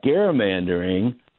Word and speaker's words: gerrymandering: But on the other hand, gerrymandering, which is actually, by the way, the proper pronunciation of it. Nobody does it.